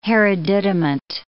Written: hereditament

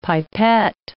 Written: pipette

